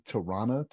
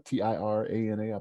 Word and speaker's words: Tirana: So, um, Albania's capital city, Tirana, T I R A N A,